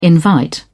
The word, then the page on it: invite: British English